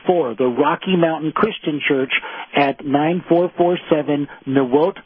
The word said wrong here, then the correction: Niwot: Road in Longmont. I hope I'm pronouncing that correctly. It's Niwot Road in Longmont.